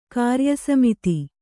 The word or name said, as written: ಕ್ರಿಯಾಸಮಿತಿ